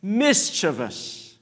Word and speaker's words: mischievous: But the emphasis, the correct way to pronounce, the emphasis is on the first syllable. It's mischievous, not mischievous, it's mischievous.